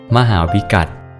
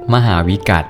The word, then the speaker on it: มหาวิกัฏ: สวัสดีครับพบกับรายการคำวัดวันนี้ขอเสนอคำว่ามหาวิกัต